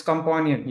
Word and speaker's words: Compawnion: It's Companion, you know, like pets are our companions, right? So it's basically Companion, but with a paw.